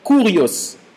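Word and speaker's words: kurios (κύριος): Le mot seigneur vient d'un mot grec que l'on prononce kurios, K-U-R-I-O-S, kurios.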